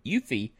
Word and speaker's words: UEFI: It adds Eufy, okay? U-E-F-I, Eufy, that's the way we pronounce it.